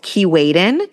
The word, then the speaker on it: Keewaydin: Another fun way to get on the water is there's this really cute island called Key Waden. It's spelled K-E-E-W-A-Y-D-I-N.